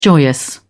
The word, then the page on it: joyous: British English